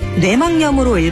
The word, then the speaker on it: 뇌막염: 뇌막염이 아니라 뇌막염으로 읽어야 바른 우리말 발음입니다.